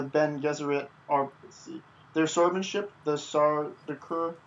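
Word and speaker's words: Sardaukar: I know that they're described of killing the how do you say it? The Sardaukins? Sardaukers? – Oh, the Sardaukers, Emperor Padishah's elite royal army.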